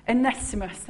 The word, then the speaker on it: Onesimus: Onesimus.